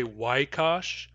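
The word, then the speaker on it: waykāš: So the fact that some speakers say waikash, waikash, W-A-Y, K, long A, S wedge, waikash, that is very unusual in some dialects. In most dialects of New Chanelth, you do not find the sequence I, which is written A-Y.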